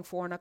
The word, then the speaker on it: Forna: N as in Nancy A, M as in Mary, I, N as in Nancy A, Forna, Forna. Follow her on the Twitters. Am I saying Forna correctly or is it pronounced something else? – Okay, good. Whew.